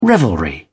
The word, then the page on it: revelry: British English